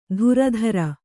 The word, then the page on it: ಧುರಧರ: kannada